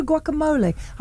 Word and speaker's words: guacamole: You've got pistachio guacamole.